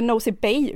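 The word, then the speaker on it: Nosy Be: vår slutdestination som var Nosy Bee, eller Nosy Bay uttalar man det, Just det.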